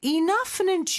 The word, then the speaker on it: enough: 그 다음에 enough는 gh로 끝나는데 요 gh가 f 발음이에요. 뒤에 오는 단어도 f로 시작을 하죠. 그럴 때는 뒤에 거를 강하게 발음해 주시면 돼요. 그래서 마치 하나의 f처럼 발음해 주시면 됩니다.